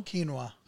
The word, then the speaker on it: quinoa: We call it quinoa. Did Yeah, I say it wrong? I No, no. it- Probably, said it right. probably you said it right. I mean, we, we pronounce it quinoa.